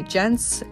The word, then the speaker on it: Jentz: My listing is under Kathy Jentz, and my last name is spelled J-E-N-T-Z.